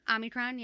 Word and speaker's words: Omicron: Omicron, yeah. – I keep seeing. – There's no N in the Omni. It's just Omni.